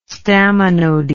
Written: staminody

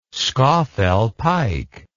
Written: scafell pike

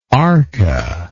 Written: arca

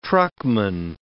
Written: truckman

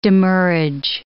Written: demurrage

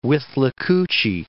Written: Withlacoochee

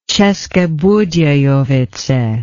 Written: Ceske Budejovice

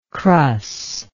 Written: crus

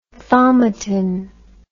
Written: thaumatin

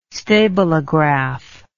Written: stabilograph

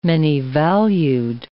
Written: many-valued

